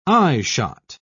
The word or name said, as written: eyeshot